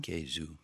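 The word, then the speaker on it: kezo: For example, cheese in Spanish is queso, but we pronounce it Quezu.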